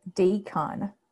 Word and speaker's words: daikon: Garlic, onions, mushrooms, potatoes, parsnips, um, Dalkin radish, no, daikon radish, and jicama spelled with a J, J I C A M A, jicama.